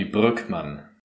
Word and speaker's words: Brueckmann: That would be Brookman.